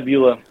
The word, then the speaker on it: Beulah: Yeah, Beulah.